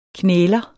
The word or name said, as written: knæler